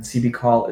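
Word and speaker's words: Si'Bickall: And CB Call is spelled S-I apostrophe B-I-C-K-A-L-L.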